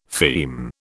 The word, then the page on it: feme: British English